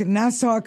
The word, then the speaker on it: Nassau: Historic Nassau Country Club. Now, I've never been there. Did I mispronounce that possibly? And no, you said it right.